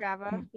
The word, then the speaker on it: Strava: No, you can find us on, we're on Strava. Is that how you say it? Strava, Strava, Strava. – Strava.